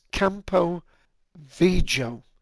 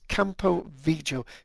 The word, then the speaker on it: Campo Viejo: And let me see, I might have to spell the second word out, but it's Campo Viejo. And Viejo is spelled V I E J O. So it's Campo Viejo.